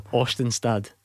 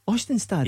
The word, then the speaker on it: Østenstad: Østenstad. Østenstad.